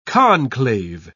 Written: conclave

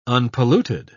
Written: unpolluted

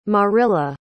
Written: Marilla

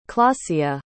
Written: Klacia

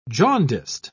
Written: jaundiced

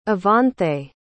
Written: evanthe

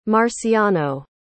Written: marciano

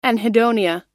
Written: anhedonia

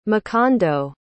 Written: makondo

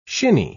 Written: shinny

